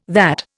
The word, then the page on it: that: Indian